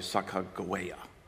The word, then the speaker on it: Sacagawea: Charbonneau, who they had hired to travel with them, had a wife from amongst the Shoshones, whose name was, we pronounce it Sacagawea. Most scholars say that it should be pronounced probably Sacagawea.